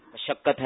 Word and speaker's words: مشقت: مشقت کا صحیح تلفظ کیا ہے, مشقت ہے یا مشقت ہے؟ – اچھا اب جواب دیجیے. – مشقت ہے درست. – جی مشقت. – میم کے اوپر کیا ہے؟ – زبر.